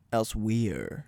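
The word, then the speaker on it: Elsweyr: is their new one, which is spelled like ELSEWEYER. – Elsewhere.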